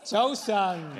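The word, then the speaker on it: 早晨: How to say this, Joe-san. – Joe-san, yeah. – Good morning.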